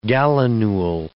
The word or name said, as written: gallinule